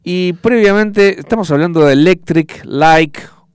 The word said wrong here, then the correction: Electric Light Orchestra: Ah, perdón, perdón, perdón, lo pronuncié mal. Electric Light Orchestra.